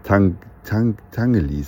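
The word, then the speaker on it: Tinguely: Man läuft an der Einstiegsstelle beim Tingeli-Museum, jetzt habe ich es wieder falsch gesagt, Tangeli sagen die Schweizer.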